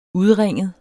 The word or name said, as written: udringet